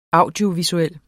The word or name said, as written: audiovisuel